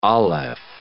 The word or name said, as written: aleph